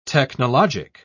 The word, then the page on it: technologic: British English